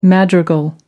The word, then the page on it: madrigal: American English